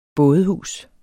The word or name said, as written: bådehus